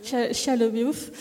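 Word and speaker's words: Shia LaBeouf: Ça se prononce comme ça, Chia Le Beouf.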